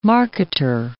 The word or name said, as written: marketer